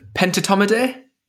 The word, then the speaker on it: Pentatomidae: I might get my pronunciation wrong here. Pentatomidae? – Yes.